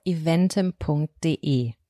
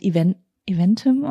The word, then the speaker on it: Eventim: Da startet sozusagen ein offizieller, exklusiver Presale auf, wir haben eben schon drüber geredet, wie es ausgesprochen wird. Ich glaube, du hast recht, es wird Eventim ausgesprochen. – Ja, Eventim, würde ich auch sagen.